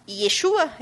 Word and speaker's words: Yeshua: E lembrando que tanto o link para Vovó chamou o diabo para ceia, para Yeshua, é isso que é assim que se pronuncia? – Yeshua.